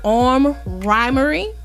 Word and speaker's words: Armed Rhymery: And I am sitting down with Armed Rhymery.